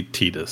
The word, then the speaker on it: Tidus: If you look at it and say like, oh, tide, it's I think intended to be Teedus.